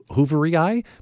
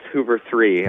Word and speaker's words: Hooveriii: It looks like, I was thinking scientific notation, so Hoovery Eye? But no, I had the same thing. It's actually pronounced Hoover 3,